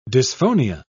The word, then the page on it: dysphonia: British English